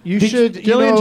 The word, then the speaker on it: Gillian: Gillian Jacobs, hard G, soft J.